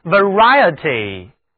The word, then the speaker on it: variety: Variety 这里的字母 A 读音可不是双元音 L，而是单元音倒写的那个而后面的那个字母组合 IE 读音是 IA，口型要大一点，来跟我读 Variety，意思就是种类，多种多样，品种。